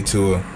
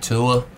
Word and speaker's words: Tua: Man, that is a sad sight to see, Tua. – Tua? – I'm praying for you, bro. That's his name, right? Mm-hmm. Yeah, that's how you pronounce it. Yeah, it's Tua.